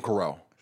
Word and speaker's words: corral: Oh, golden corral. – Corral? – Yeah. – Golden coral. – Like coral would be like coral reef. And then like corral. Corral is like to bring together or something.